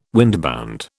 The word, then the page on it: windbound: British English